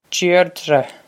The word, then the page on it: Deirdre: Jayr-dreh